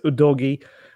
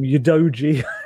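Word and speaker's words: Udogie: It's Udogi, and I've been calling him Udoji in recent days as well. So a bit like Paratici, how we used to call him Paratici. It's probably going to take me a little while to switch my brain into the actual correct pronunciation.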